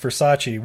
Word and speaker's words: Versace: No, if there's one thing I learned from Marshall Mathers, it's pronounced Versace.